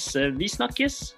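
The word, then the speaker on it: Vi snakkes: and that is visnakkes, which means see you later in Norwegian. – Oh, I love it. Okay, how do you say this? – Visnakkes. – Visnakkes. – There you go. Perfect.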